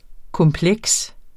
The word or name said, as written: kompleks